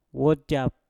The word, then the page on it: wojap: mpur